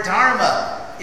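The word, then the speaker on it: dhar (धृ): So anyway, in Sanskrit, the word dharma is traced to the root dhar. That's how to pronounce it.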